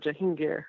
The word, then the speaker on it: Jahangir: And that very year, or close to, he did have a son, Jahangir, who took over, or Jahangir, I think it's a better pronunciation.